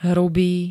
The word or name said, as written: hrubý